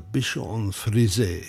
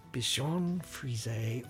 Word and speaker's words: Bichon Frise: The Bichon Frise. – The Bichon Frise, or the Bichon Frise, if you don't pronounce French very well.